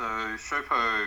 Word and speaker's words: Showpo: Is it Showpo? – I hope I pronounced that right. – Yeah. So Showpo,